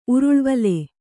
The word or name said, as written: ಉರುಳ್ವಲೆ